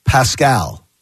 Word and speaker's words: Pascal: Zach Pascal, as I was told to pronounce it yesterday. Isn't that from from one of the Disney movies? – Yeah, it's from Tangled. – From Tangled, Pascal.